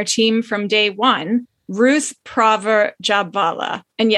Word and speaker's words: Prawer: We've been thinking the whole time it was Ruth Prawer Jhabvala, but because she was from Germany and they pronounce the W as a V, I believe it's Ruth Praver Jhabvala.